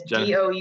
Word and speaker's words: Doudna: It's Doudna.